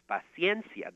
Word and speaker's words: paciencia: And I said it was paciencia. And actually, it's paciencia. That's how you officially pronounce it in Spanish.